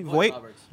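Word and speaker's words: Vogt-Roberts: Jordan Voight Roberts, did I pronounce that correctly? – Voight Roberts.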